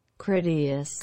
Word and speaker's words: Critias: Okay, Critias. Is going to... – Ready? – Critias.